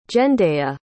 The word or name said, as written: Jendaya